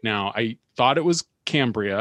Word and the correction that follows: Cambria: and I got corrected by some very friendly fans of the podcast. – Wow. – And it's a, I believe it's a long A. It's Cambria, like Cambridge.